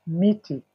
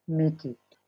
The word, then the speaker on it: miti: The plural is miti. – Michi. – Yes, miti.